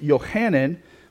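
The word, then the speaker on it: Yohanan (יוחנן): And in the Hebrew, it is pronounced Yohanan, which means the Lord has shown grace.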